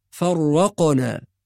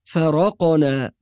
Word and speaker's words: فرقنا: أيضا من المواضع التي يخطئ فيها بعض الناس الآية رقم 50 في قوله سبحانه وتعالى وإذ فرقنا بكم البحر، كلمة فرقنا بعض الناس ينطقها بالتشديد فيقول فرقنا، والصواب أن الراء مفتوحة غير مشددة، فنقرأها فرقنا ولا نقول فرقنا.